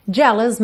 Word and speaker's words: jealous: Jealous minds, jealous, jealous. Atenção aqui também, você não diz os, jealous mind.